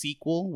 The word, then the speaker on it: SQL: You use SQL, which I figured out that's how you pronounce it. – Yeah. – SQL. – Yeah. – I didn't know that. I thought it was SQL. And then now that I'm taking courses, they're like, yeah, SQL. I'm like, what the fuck? But yeah, SQL is how you pronounce the 3 letters.